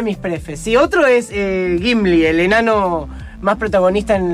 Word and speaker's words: Gimli: Gimli, con G-I, sin U.